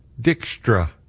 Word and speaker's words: Dijkstra: The last three, all that wonderful noise coming from Jorrit to Di, how do you say his last name? Dijkstra. D-I-J-K-S-T-R-A.